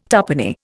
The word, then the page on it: tuppenny: Indian